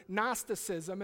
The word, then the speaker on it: Gnosticism: The G is silent on this word, Gnosticism.